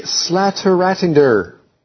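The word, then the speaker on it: Slættaratindur: The island's highest peak, let's see if I can pronounce this correctly, Slateratindr. Yes. – That sounded great. – Thank you. Did it sound Danish? – It sounded very Danish.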